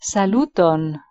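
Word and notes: saluton: Esperanto

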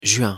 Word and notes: juin: French

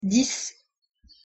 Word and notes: dix: French

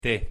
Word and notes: t: Spanish

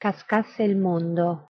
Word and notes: cascasse il mondo: Italian

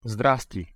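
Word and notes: sdrasti: Bulgarian

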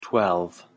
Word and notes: twelve: English

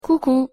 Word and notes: coucou: French

